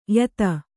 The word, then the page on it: ಯತ: yata